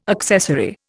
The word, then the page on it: accessary: Indian